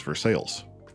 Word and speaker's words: Versailles: Yeah, I mean the one that I remember the most from my childhood in Ohio is there's a Versailles in Ohio, but it's Versailles. – Sure. It's like Des Moines.